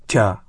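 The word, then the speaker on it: тя (茶): Китайцы – ча, а японцы – тя.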